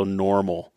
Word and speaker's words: NORML: Now, one of the biggest organizations that we have that are pro-marijuana in the state of Colorado is Colorado Normal, and it's spelled N-O-R-M-L.